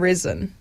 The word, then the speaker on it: Rezn: And then the second one was Devotion from Rezin. That is spelled R-E-Z-N.